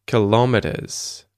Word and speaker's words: kilometers: I guess that's the second syllable right there. Kilometers. Kilometers. But yeah, you could say kilometers. Kilometers. And I think that would be the secondary stress would be on the first syllable, kilometers. And the primary stress would be on the third one, meters.